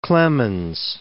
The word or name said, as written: Clemens